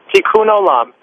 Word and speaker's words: Tikkun Olam: Tikkun Olam. – Tikkun Olam? How do you spell it? – That's right. T-I-K-K-U-N.